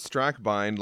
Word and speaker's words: Strackbine: I pronounce it Strackbine like Einstein, which seems to be the East Coast way of saying it.